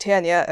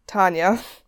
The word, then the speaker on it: Tanya: I also learned that her name is pronounced Tanya and not Tanya, so that was cool.